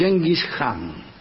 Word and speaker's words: Genghis Khan: A mí me enseñó Roy Bartholomew que sería el Genghis Khan. que la K y la H se pronuncian J, al menos en la casa de Roy Bartolomeo.